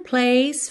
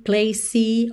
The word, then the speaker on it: place: Então, muito cuidado aí para você não falar time, place, ok? Time. O som termina no M, time. Place, place. O som termina nesse, parece um S, né?